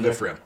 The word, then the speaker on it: Ephraim: so Samuel's father, and they're living in Ephraim. Mm-hmm. Did I pronounce that right? – Yep. – Ephraim? – So which is north.